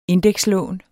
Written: indekslån

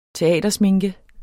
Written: teatersminke

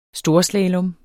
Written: storslalom

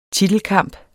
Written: titelkamp

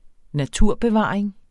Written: naturbevaring

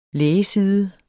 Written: lægeside

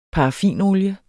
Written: paraffinolier

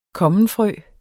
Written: kommenfrø

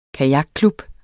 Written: kajakklub

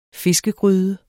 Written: fiskegryde

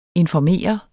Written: informere

